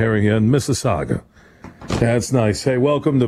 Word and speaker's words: Mississauga: How do you say the city? Mississauga. – Mississauga. – Mississauga. Okay. Well, yeah. M-I-S-S. Yeah, it's like our M-I-S-S-I-S-S-I-P-P-I, the American version.